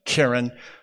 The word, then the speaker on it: keren: Segalit nouns have the stress on the first syllable, so you would pronounce it keren.